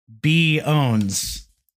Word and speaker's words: pwns: But you're not supposed to pronounce the P. So it's pronounced B owns.